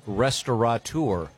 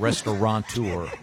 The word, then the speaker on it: restaurateur: The most mispronounced is restaurateur. – Mm-hmm. – There's no N. It's not restaurateur.